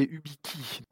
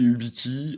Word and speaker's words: Ubikey: J'ai dit Ubiquet, mais c'est Ubiki. Tout à fait, oui. Moi, je dis Ubiki. Il y a pas mal de clients qui le prennent prononce autrement. Ça peut prêter à confusion, on est d'accord, il n'y a, pas de problème là-dessus. On répond aux deux noms.